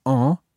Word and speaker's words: en: And notice what happens with the pronunciation of en there. Normally, en is quite a nasal sound. En. – En. – But when we say il y en a, that you sound the N again.